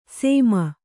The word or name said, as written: ಸೇಮ